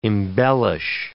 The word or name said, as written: embellish